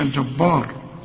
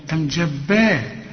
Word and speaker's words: الجبار: الجبار هنا الراء المفخمة، سواء هتوصل او هتقف لانها مضمومة، وهنا ما تقولش الجبار، خد بالك الجبار با، لا الجبار، واخد بالك الجبار، الراء بس هي المفخمة.